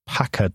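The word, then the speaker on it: PACCAR: But what about this Packer judgment, if I'm pronouncing that correctly? – Yes, yes.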